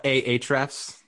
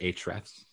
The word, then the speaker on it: Ahrefs: Some people call it Ahrefs. But I think they pronounce it Hrefs. – They're wrong. Those people probably call them GIFs too.